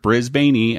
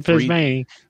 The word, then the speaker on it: Brisbane: Similar to Melbourne, Brisbane often is pronounced as it's spelled. Brisbane.